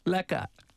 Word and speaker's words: lekker: And then the answer, if you're doing well, "lekker, bro." – Lekker.